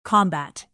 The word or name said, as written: combat